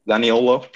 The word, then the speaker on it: Zaniolo: Zaniolo.